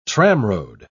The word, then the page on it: tramroad: British English